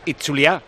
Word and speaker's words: Itzulia: Euskal Herriko Itzulia, o sea, lo he dicho mal toda mi vida, es Itzulia. – Bueno, es Itzulia. – Es más acentuado la Itzulia. – Es Itzulia. La Itzuli, ¿no? Porque Itzulia es como Osasuna.